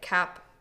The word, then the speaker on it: Cap'n: Cap'n, and the reason I'm saying it like that is that's actually how it's spelled, like Cap'n. So it's okay. Captain, but it's written as Cap'n.